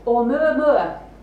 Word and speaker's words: Oumuamua: You pronounce it Oumuamua. Okay? You're going to have to practice that later. And just to say, this apostrophe, well, it's not really an apostrophe, this is actually a proper sound in the Hawaiian language, which is more like, you know, not quite a throat clearance or a glottal stop.